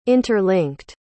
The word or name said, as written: interlinked